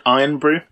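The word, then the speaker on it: Irn-Bru: They have Urn Brew in Scotland, don't they? – They pronounce it Iron Brew. – Okay, my bad. – It's like a bastardization spelling of the word iron. – Iron Brew.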